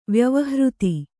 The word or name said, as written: ವ್ಯವಹೃತಿ